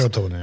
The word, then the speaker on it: Crotone: Det var 5-0 till Roma mot Crotone. Eller hur det uttalas? – Crotone, ja.